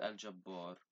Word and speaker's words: Al-Jabbar: And the last name for this episode is Al-Gabbar, meaning the omnipotent. Pronunciation. I'm sorry for the pronunciation. It's called Al-Jabbar.